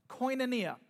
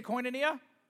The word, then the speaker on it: koinonia: And that word fellowship is a Greek word pronounced koinonia. Can you say koinonia? Okay, koinonia.